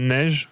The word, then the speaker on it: Neige: And uh, your name, uh, how do you pronounce it? Niche? It's uh, Neige, and my real name is Stefan.